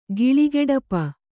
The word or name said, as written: ಗಿಳಿಗೆಡಪ